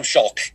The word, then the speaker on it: Schalk: I think he said it pronounced it like it's rhymes with shock. Tom Schalk got the part.